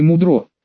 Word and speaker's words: Мудро: Это как, Мудро или Мудро? Мудро, с ударением на первый слог, дрожащим от напряжения голосом ответил Ярик, волнуется.